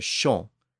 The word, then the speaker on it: chant: Modern French still has that word chant, pronounced today as chant. It's still spelled C-H-A-N-T in French, but it doesn't have the same meaning as the English word.